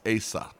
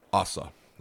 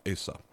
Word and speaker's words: Asa: Abijah reigned for 3 years, so we're going to kind of skip him and go on to the second king, and his name is Asa, or some people pronounce it Asa. It's spelled A-S-A, Asa.